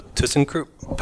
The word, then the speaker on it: ThyssenKrupp: But uh, so ThyssenKrupp.